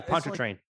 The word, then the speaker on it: Pontchartrain: There's another one. The Pontchartrain. – Yeah, Pontchartrain. Like, yeah, the Pontchartrain Causeway. – Yeah, exactly. – That's the lake that's. Yeah, it's like I only know how that's pronounced because I heard it in a song. If I read that, it'd be like Pontchartrain.